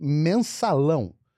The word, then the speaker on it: Mensalão: It's called Mensalão.